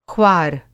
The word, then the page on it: ख़्वार: خوار